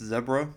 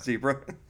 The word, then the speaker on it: zebra: It's pronounced zebra, not zebra.